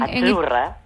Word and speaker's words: azzurra: Azzurra. – Azzurra. Mira, ¿ves? – Azzurra. Es azzurra. Que... Sí, como porque pizza. La zeta no es la zeta como es decimos en catalán tan sonora, no.